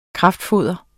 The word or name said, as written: kraftfoder